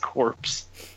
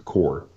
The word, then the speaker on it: Corps: And it's not pronounced corpse. – I don't see how this in any way, shape, or form is going to be pronounced core. – Because language. Because that's how the English language works. It doesn't work well, but it's how it works.